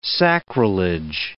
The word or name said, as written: sacrilege